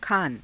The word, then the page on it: khaana: Hindi